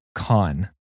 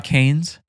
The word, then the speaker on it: Cannes: But I just discovered the film festival is pronounced con. – No. – Yeah. – It's not Cannes? – It's not Cannes. It's French. Con.